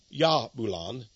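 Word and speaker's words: Jahbulon: He is giving the sign, the hidden sign of Jabulon, or Yabulon, if you'd like to pronounce it like a Y. But it's J-A-H-B-U-L-O-N.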